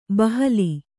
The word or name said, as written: ಬಹಲ